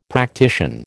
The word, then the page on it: practician: American English